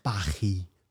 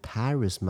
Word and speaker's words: Paris: 他法文常常写一些字然后不发音，比如说巴黎的法文是巴黑，那你说不是念 Paris 吗？那 Paris 是是英美人会讲的，可是对法国来说是巴黑。